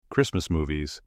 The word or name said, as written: Christmas movies